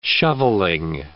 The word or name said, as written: shovelling